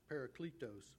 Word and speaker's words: Parakletos: And in the Greek, the Holy Spirit is pronounced Parakletos, which para means alongside, and kletos means helper.